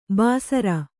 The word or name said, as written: ಭಾಸುರ